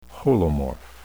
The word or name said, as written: holomorph